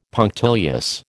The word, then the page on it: punctilious: American English